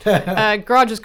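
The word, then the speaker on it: garage: Okay, so these guys who make garages, got to correct your North American pronunciation there, Hamish. garages, carports, and sheds in Virginia.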